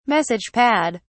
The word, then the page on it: message pad: British English